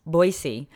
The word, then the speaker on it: Boise: It's a convention out in Boise, soft S, Boise.